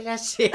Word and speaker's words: Laschet: Isu, lâché, lâché. – Richtig. Wir werden uns weigern, ähm, den Namen Armin Laschet deutsch auszusprechen. Er wird nur französisch ausgesprochen in diesem Haus.